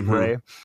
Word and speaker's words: Ra: And I think Corey Good believes, because the guy went by the name Ray or Ra, pronounced Ray.